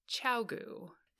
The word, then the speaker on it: ChiaoGoo: We are going to actually ask them the correct way to pronounce their brands. And it's chow goo.